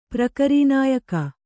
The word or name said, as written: ಪ್ರಕರೀನಾಯಕ